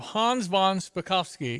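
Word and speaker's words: Hans von Spakovsky: We have Hans Von Spakovski.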